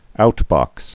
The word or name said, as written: out box